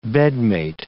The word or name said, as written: bedmate